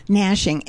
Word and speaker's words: gnashing: It always has been, gnashing. And when you spell gnashing, it's a silent G, G-N-A-S-H-I-N-G.